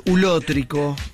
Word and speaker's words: ulótrico: La palabra de hoy, la primera palabra es ulótrico. – Uy, ulótrico. – ¿Qué es ulótrico? Con acento en la O.